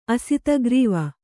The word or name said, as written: ಅಸಿತಗ್ರೀವ